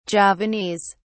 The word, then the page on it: Javanese: British English